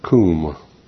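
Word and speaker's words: Koom (קום): Now, the word translated arise, interestingly enough, is Koom, K-O-O-M, is how it's pronounced. It's spelled K-U-A-W-M.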